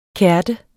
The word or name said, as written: KERTERNES